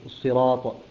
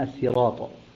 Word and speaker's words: الصراط: كلمة الصراط الأصل فيها السين، لأنه من صرطت الشيء إذا ابتلعته، فأبدلت صادا لتوافق الطاء في الاستعلاء، فأبدلت طاء صادا لتوافق الطاء في الاستعلاء حتى يكون هناك يعني توافق في النطق بها، الصراط تكون أسهل من السراط.